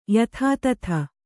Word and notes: ಯಥಾತಥ: yathātatha

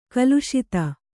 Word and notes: ಖಾಲಸಾತಿ: khālasāti

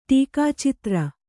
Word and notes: ಟೀಕಾಚಿತ್ರ: ṭīkācitra

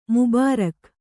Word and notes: ಮುಬಾರಕ್: mubārak

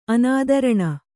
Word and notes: ಅನುತ್ತೀರ್ಣ: anuttīrṇa

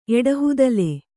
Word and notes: ಎಡಹುದಲೆ: Kannada

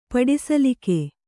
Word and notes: ಪಡಿಸಲಿಕೆ: paḍi salike

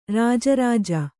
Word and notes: ರಾಜರಾಜ: Kannada

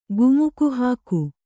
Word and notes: ಕುಮಕಿಹಕ್ಕು: kannada

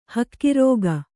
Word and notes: ಹಕ್ಕಿರೋಗ: kannada

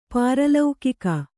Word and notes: ಪಾರಲೌಕಿಕ: pāra laukika

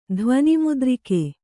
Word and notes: ಧ್ವನಿಮುದ್ರಿಕೆ: dhvani mudrike

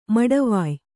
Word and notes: ಮಡವಾಯ್: maḍavāy

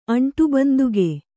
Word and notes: ಅಂಟುಬಂದುಗೆ: Kannada

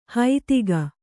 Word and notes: ಹೈತಿಗ: haitiga

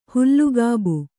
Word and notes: ಹುಲ್ಲುಗಾಬು: hullu gābu

